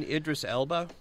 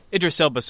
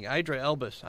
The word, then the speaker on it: Idris Elba: Are you do you mean Idris Elba? – Idris Elba, sorry, I mispronounced his name. – Yeah, I was thinking Idra Elbus.